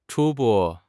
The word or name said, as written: 初步